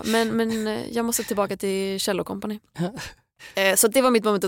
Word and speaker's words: Kjell & Company: Jag vill instinktivt säga Kjell & Company för att det är så min pappa har mispronounced it hela livet. Som att han pratar om en cello.